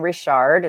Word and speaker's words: Richard: You can find me on Facebook and Instagram at either Dawn Richard, it's spelled like Richard, it's just French, or The Awakening with Dawn.